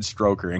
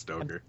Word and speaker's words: Stoker: The last couple of videos I've watched, people have shown off Bram Stoker stuff and said Stroker and corrected themselves. – Why? – I don't know why everyone says like Stroker. It's so weird. – Maybe I'm talking. – Why should it be pronounced that way? And me and you are the idiots. – No, it actually is Stoker.